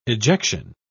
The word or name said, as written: ejection